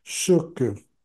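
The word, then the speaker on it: Sökö: But what makes it tricky is that both of the letter O's in that word have an umlaut over them, which makes the pronunciation "sucker."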